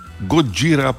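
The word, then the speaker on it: Godžira (ゴジラ): Godzilla je angliško izgovorjena Godzilla. Godžira pa pomeni na eni strani zlitje gorile, ki je gorila, in kudžira, ki je kit. Torej, ne, kit. Kit gorila ali gudžira, oziroma kot rečejo američani, Godzilla, okay.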